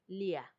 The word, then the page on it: lia: Macuiltianguis